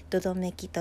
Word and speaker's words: 百々目鬼: 百百目の鬼と書いてどどめきと読みますがご存知でしょうかどどめきも第 1 回の 6 6 尾同様に分類としては人が妖怪化したものと言えます何をするとどどめき